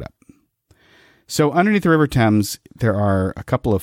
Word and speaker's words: Thames: Yeah, this is in London under the River Thames. Am I pronouncing that right? – You are.